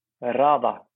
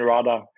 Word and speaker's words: hrada: RADA is the name of our new project. RADA. – You might have to spell that one. – Yeah. It's H-R-A-D-A. H-R-A-D-A. It's an RADA.